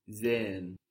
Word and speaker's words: sehen: Sehen is spelled S-E-H-E-N. And again, we have that H stretching out the vowel. – Sehen. – Unless the word is pronounced really slowly or emphatically, you're not going to hear that H. It's just an extension of the vowel. But if somebody pronounces the word slowly for you, you might hear sehen. You might feel like you're hearing that H. But in fluent speech, you won't hear it.